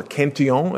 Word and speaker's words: Cantillon: Probably the first sort of explicit formulation of this very general idea comes from the famous French economist Richard Cantillon, or Cantillon, if you prefer the French pronunciation.